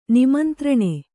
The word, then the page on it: ನಿಮಂತ್ರಣ: nimantraṇa